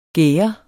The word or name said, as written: gærde